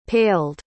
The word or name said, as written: paled